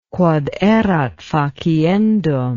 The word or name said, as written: quod erat faciendum